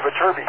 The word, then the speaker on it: Viterbi: Oh, Viterbi.